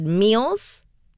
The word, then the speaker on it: Meels: Before that, we also heard new music from an artist called Meals. It is spelled M-E-E-L-S.